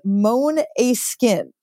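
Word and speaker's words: Måneskin: and it says it's pronounced Maneskin.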